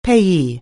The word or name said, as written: payee